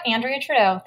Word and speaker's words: Andrea Trudeau: Yep, Andrea Trudeau.